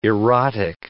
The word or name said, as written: erotic